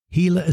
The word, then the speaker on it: Gila: Gila is spelled G-I-L-A if you want to look up Gila monster. But it's pronounced like the letter H.